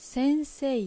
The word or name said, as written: Sensei